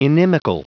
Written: inimical

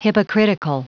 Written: hypocritical

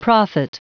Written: prophet

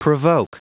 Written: provoke

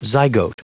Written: zygote

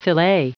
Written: filet

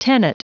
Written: tenet

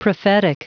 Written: prophetic

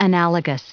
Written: analogous

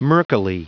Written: murkily

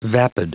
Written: vapid

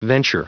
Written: venture

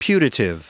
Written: putative

